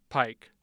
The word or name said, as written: Pike